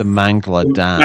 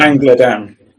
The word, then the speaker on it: Mangla Dam: The Mangla Dam. – Mangla Dam.